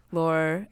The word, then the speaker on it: Laure: It has the coming of age style narrative where, oh yeah, once Laure. – Laure, yeah. It's like French pronunciation is confusing me.